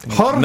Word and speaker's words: Jorge: Jorge, now you're Garcia! Getting into it. I'm just Adam's caught the fever. I'm just pronouncing his name accurately.